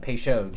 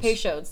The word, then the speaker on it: Peychaud's: Did I say that wrong? – Peychaud's. – Peychaud's, there we go.